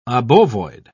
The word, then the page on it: obovoid: British English